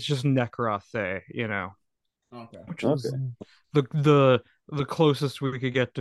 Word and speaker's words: Nekroí: Nekroí is the correct pronunciation of that. I know it's Greek. Well, according to Reddit, it's the correct way.